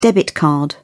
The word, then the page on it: debit card: British English